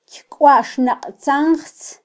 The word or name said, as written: kykwaxnaqtzanjtz